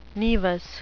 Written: nevus

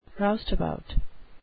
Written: roustabout

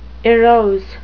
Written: erose

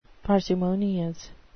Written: parsimonious

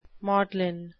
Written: maudlin

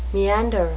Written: meander

